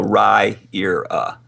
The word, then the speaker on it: Riyria: So Raira is how it's pronounced.